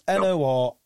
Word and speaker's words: Norilsk: Have you ever heard of a town called Norlistic? I think I'm saying it right. And I think it's Norilsk.